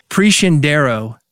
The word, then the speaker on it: Preciandaro: It's Preciandaro is how you say Gabby's last name. And here it is spelled out for you so you can find her. Preciandaro. Gabby Preciandaro, thank you for being here on Music Business Radio.